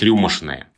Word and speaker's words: рюмочное: Но ведь рюмочное или даже рюмочное, мне кажется, это надо произносить рюмошное.